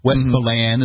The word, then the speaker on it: Westmoreland: Westmoreland is how they pronounce it here. Westmoreland, New Hampshire.